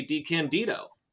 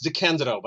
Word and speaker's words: DeCandido: DeCandido. – Thank you very much for having me. I should say it's pronounced DeCandido, by the way.